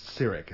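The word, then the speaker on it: Cirroc: I believe his name is pronounced Cyric. It's C-I-R-R-O-C.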